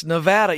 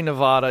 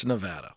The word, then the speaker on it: Nevada: Well, first, I just want to appreciate you saying Nevada right. – I got to tell you, I said it wrong enough to finally get it right. It's Nevada. Even though I want to say Nevada, it's Nevada.